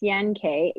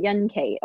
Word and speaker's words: Yan Ke: If you were in Chinese, they call me Yan Ke or KK is what my nickname is, you know, my Chinese nickname. Most white people pronounce it Yan Ke, which is fine. they're doing their best.